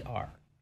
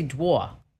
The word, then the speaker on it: drawer: How do you say drawer? How do you say that word? – Drawer. – Okay. See, you're from New Mexico. I'm from New Jersey. We say dwa. Okay, dwa.